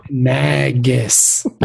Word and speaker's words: Nagus: Nagus. Nagus. Nagus. Nagus. – Nagus. – So we jump from Jake and Sisko to just change the N to an M and you've got Magus, and that's cool.